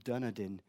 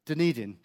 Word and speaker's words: Dunedin: And it was looking at a population survey, a large-scale population survey in New Zealand, the so-called Dunedin genetic survey. What is it? Dunedin, sorry. Pronunciation.